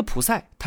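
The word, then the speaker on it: 普 赛 (ψ): 方程当中有一个波动函数是用希腊字母表示的，长得就像是海神波塞冬手里的三叉戟一样，我们读作普赛。那这个普赛它究竟是什么？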